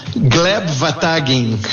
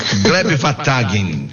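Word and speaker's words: Gleb Wataghin: Gleb Vatagin. – Gleb Vatagin.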